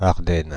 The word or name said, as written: Ardennes